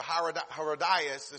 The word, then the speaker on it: Herodias: And so her daughter, Herodias, or Herodias, as some people pronounce it, she sent her daughter out to dance before Herod and his friends.